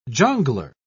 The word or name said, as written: jongleur